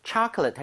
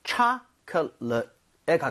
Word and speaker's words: chocolate: chocolate 할 때는 앞에 O의 강세가 가죠. 그러면서 입모양이 좀 벌려지겠죠. – 예. – So, 초콜릿보다는 chocolate에 가깝고요.